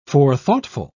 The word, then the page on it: forethoughtful: British English